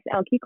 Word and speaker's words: Elke: Is it Alike? – It's Elke. – Elke, I apologize. Welcome to the stage. Please introduce yourself. – Hello, everyone. My name is Elke Crawford, and I am the other co-founder of Short-Term Residences.